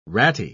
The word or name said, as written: ratty